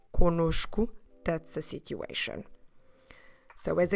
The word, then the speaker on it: conosco: So notice that the first syllable is again nasal, com, and then the second syllable, because it has a different N, will actually have the sound of nosh. So com nosh. And then we finish with cu, conosco, stressing the middle one, nosh.